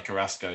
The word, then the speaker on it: Carrasco: Carrasco, is that how? Yeah, Carrasco.